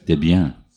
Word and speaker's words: Debian: and I always want to pronounce Debian as Debian. I don't know why, because it's just Deborah and Ian, isn't it? – Yeah, Debian.